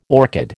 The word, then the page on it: orchid: American English